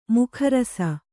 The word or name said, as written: ಮಕರಿಸು